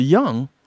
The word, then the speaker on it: Jung: Carl Jung, or Carl Jung as it is pronounced,